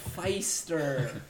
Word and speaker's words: Pfister: It's a P-fister design. – Feister. – Oh, Feister. That's what it is.